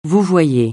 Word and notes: vouvoyer: francais

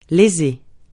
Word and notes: léser: francais

